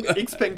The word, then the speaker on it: XPeng: Li Auto und Xiaopeng tatsächlich in dieser Woche. Entschuldigung, habe ich nicht mal richtig ausgesprochen. – XPeng kannst du auch sagen. Das ist ja dann die amerikanische oder deutsche Variante.